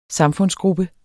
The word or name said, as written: SAMFUNDSGRUPPEN